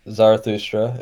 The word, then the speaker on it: Zarathustra: I don't know how you would pronounce it in English. Yeah, Zarathustra, exactly. Yeah.